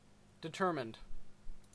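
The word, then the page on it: determined: Canadian English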